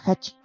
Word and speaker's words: Hachi: Aquel kanji se pronuncia Hachi, por lo que se convirtió en el origen de su hoy famoso nombre.